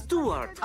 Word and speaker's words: Stewart: ¿Se pronuncia así? – ¡Stewart!